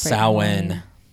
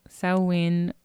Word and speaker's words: Samhain: Yeah, Samhain, or it's kind of spelled like Samhain, but it's right. like Gaelic, so it's totally, yeah. it's always pronounced like in a way that's completely different from the way that it yeah.